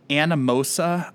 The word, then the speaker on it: Anamosa: 1891 in Anamosa, Iowa.